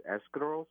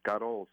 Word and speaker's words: escarole: The correct pronunciation is escarole soup. If you were brought up where I grew up in South Philly, we call it scuttle soup.